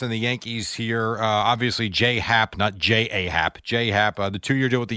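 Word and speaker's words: J.A. Happ: Right, but he wants it pronounced J-Hap.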